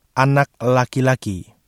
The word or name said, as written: anak laki-laki